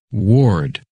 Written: Ward

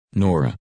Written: Nora

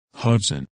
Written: Hudson

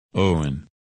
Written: Owen

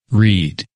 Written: Reed

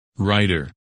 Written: Ryder

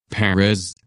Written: Perez

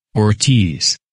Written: Ortiz

